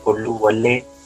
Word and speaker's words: Oluwole: Israel Olowole Olofinjana.